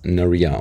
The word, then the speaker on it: Naria: Ich persönlich sehe es als für mich ist es ein Mädchenname und ich selber spreche es als Naria aus, also mit Betonung auf die zweite Silbe.